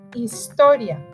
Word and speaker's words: historia: Math, matemáticas, algebra, álgebra, geometry, geometría, calculus, cálculo, social studies, ciencias sociales, history, historia, remember the H is silent, geography, geografía, Science, ciência.